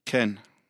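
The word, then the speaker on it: Ken (כן): Ken. – Ken.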